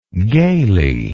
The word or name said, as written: gayly